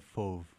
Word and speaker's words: Fauve: Tell me, Jeremy, how to pronounce that correctly. – It's Fauve. Fauve is great.